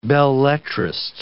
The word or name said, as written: belletrist